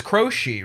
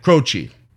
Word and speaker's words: Croce: Jim Croce, because it's Croce, right? – Yeah. – That's how you say it. – Croce.